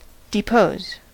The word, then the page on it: depose: American English